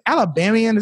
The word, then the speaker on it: Alabamian: Now, I don't is it Alabamian? Is that the right way to say that? Uh, that works. Yeah, that's it, man.